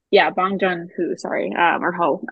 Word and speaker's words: Bong Joon-ho: Is it Jung Ho? – I just watched it recently. – And it was, Bong Joon-ho, and it's one of his like earlier movies from the 80s, but it was absolutely fantastic.